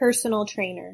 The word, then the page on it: personal trainer: English